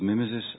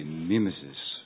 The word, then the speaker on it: mimesis: So, after this long pause, I come back with this strange problem of mimesis, the politics of mimesis, and I will pronounce this word in this way, mimesis. I can't bring myself to the American pronunciation of mimesis. So, okay, mimesis.